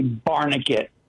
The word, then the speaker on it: Barnegat: Am I saying that right? – It's actually Barnegat. – Barnegat. – You did fairly well. – E-T, okay. Yeah, Barnegat.